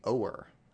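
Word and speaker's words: Ohr: Also working on this report, well, actually, she started the report before Chris was even hired, was Nellie Oher. I'm guessing that's how you pronounce her name. It's O-H-R, Nellie Oher.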